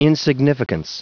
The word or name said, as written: insignificance